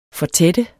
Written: fortætte

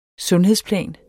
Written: Sundhedsplan

